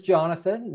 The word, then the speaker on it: Jonathan: It is Jonathan. Now okay. you know I go by John or Jonathan, but uh, you know just don't call me late for dinner and we'll be okay.